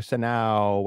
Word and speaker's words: Sejnow: And we're joined by very special guest, our good friend, Heather Sejnow.